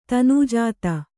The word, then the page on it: ತನುಜಾತೆ: Kannada